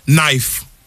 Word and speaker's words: knife: Knife. – Oh, yeah. – Knife. This will sometimes throw me when I see it, because I always want to say knife.